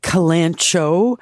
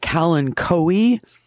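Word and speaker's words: Kalanchoe: Okay, so just to be clear for our other listeners, I would call it calancho. Some people call it calancoe, depending on how we pronounce our Latin.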